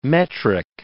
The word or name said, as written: metric